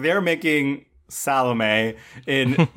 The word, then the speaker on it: Salome: by the way, I'm not correcting you. It's just you're pronouncing it the way they pronounce in the movie. It's Salome. – Salome. – That's how you say it.